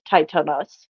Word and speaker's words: Tithonus: It's spelled Tithonus, but it's Titanus.